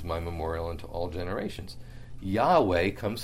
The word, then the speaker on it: Yahweh: But in the Hebrew, it's often pronounced Yahweh, because the J in Hebrew is pronounced like a Y. That's why it's Yahweh. Or if it were Jehovah, it would be Yehovah. But they would probably pronounce it Yahweh. So it's Yahweh, God of your fathers.